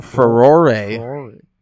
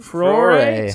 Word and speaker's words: furore: Furore. So it's like foray or foyer. – But it's spelled like furor. Look at how it's spelled. F-U-R-O-R-E, but it's spelled, but it's pronounced furore. Like, how the fuck am I supposed to know that? – The good old English language, man.